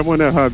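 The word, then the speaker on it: Monnerat: Como é que é essa pronúncia, André? Fala pra gente, cara. Oi, é André Monerá mesmo.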